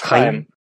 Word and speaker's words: Chaim: Sorry, it's Hebrew. It's Chaim. Yeah, it's the sound. No. If it helps, just put a K. Chaim is kind of close.